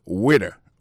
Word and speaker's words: waiter: Alors moi je prononce le waiter.